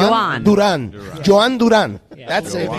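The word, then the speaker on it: Jhon Durán: It's a soft J, so it's Joanne. – Soft J, Joan Duran.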